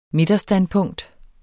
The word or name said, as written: midterstandpunkt